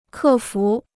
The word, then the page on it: 克服: kè fú